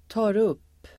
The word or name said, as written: tar upp